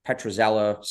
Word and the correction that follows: Petriello: stat guy. – Mike Petriello, yeah. – Petriello, there we go.